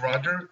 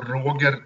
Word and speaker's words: Roger: My name is Roger, or Roger Tallroth, as I pronounce it in Sweden.